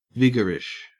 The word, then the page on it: vigorish: Australian English